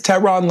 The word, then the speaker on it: Tehran: My name is Tehran, like the capital of Iran.